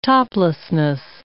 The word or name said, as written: toplessness